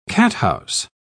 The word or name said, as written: cathouse